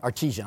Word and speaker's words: artesian: They said they hit an artisan well, if I'm saying it right, artesian, I'm sorry, artesian well.